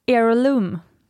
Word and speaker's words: heirloom: Sen så berättar de också om vad hero, eller heirloom, så uttalas det väl, jewelry är.